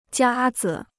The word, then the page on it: 夹子: jiā zi